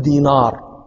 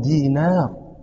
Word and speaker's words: دينار: النون رقيقة، النون حرف رقيق، لا نقول دينار، هذا نطق عجمي، نحن عرب نقول عبد الله بن دينار، دينار نون هذا حرف رقيق أعرابي، يعني لتفهموا وتدركوا هذا الذي كان يحسه الأعراب في ذلك الزمن.